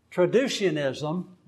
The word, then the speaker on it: traducianism: Pre-existence, so pre-existentism, traducianism, that's a soft C, and creationism.